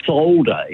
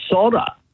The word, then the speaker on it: solder: Another one I've noticed a lot is we say solder, if we're going to solder something together, whereas the Americans say solder. – There's no L.